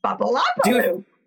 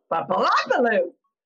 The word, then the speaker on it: Bubbalopaloo: Kids just say it like that. Bubbalopaloo. – Bubbalopaloo? – Bubbalopaloo. – There we go. Okay. I got it.